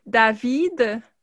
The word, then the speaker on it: Daveed: Dedans, c'est, j'ai tout un la à prononcer ce nom-là, mais c'est David Diggs. C'est comme D-A-V-E-E-D.